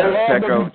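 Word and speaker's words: Peko: Pecco, Gino. Tackles. You said it right.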